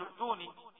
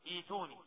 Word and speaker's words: ائتوني: ايتو، كما في قوله تعالى في سورة الاحقاف ايتوني، ما ينفعش تقول ائتوني، ايتوني.